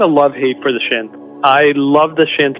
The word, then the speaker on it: Shnth: A Shnth. I think that's how you say it. Yeah, the shinth. Yeah.